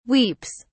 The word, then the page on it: weeps: British English